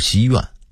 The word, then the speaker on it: 西苑: 西园的读音应该是西苑，木樨地的读音应该是木樨地，原来此处为养马之地，种有大量的苜蓿，因此得名木樨地，后来改名木樨地等等。